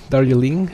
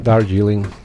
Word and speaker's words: Darjeeling: So, little Darjeeling, how do you pronounce that? Darjeeling. – Yeah, little Darjeeling. – Darjeeling. – Like the tea. – Like okay. – Darjeeling tea. Yeah.